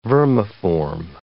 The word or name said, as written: vermiform